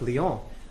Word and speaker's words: Lyons: Lyon in France, spelled L-Y-O-N-S. The S is silent.